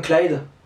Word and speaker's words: Clyde: Tu dis Clyde, tu dis pas en, plusieurs syllabes. – D'accord. – De loin, ça peut, ressembler, ça peut ressembler à Claude aussi.